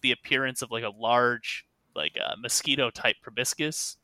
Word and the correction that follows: proboscis: That's not how you pronounce that. Mosquito-type like needle, proboscis. There we go.